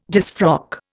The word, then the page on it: disfrock: Indian